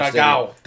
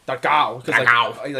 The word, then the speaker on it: Dragão: Dragão. Dragão. Like, the Portuguese have like a ow.